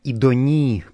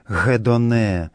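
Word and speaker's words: идони (ἡδονή): В греческом тексте этой стихиры употребляется на месте церковнославянского слова «сласть» слово «идони», которое в классическом, в древнем греческом произношении звучит как «гедоне».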